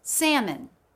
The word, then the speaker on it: salmon: Salmon. Locks. – Where can you Google where the word salmon is from? Like, what's the origin? This is Latin. – The L in salmon was never pronounced in English, and its presence in the spelling is a result.